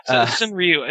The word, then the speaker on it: Senryu: Senru. Am I pronouncing that right? – Senryu, I think. – Senryu.